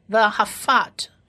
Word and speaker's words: v'ahafat: 这个辅的音呢，加上下面的这一个中文，像中文一样 1234 的这个一，它是短母音 r，所以就是发辅 r fa fa fat v'ahafat 的 fa，在最后一个也是一样是希伯来文字母的 tav 放在字尾，发音发特的音，所以这整个字就是 v'ahafat，是以及爱的意思。